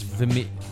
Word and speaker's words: Vimeo: I assume it's like video, so it's Vimeo. Vimeo. Yeah, that's Vimeo.